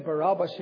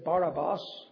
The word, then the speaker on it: Barabbas: And like, for instance, he, instead of saying Barabbas, he would say Barabbas, which probably is the more correct pronunciation of it.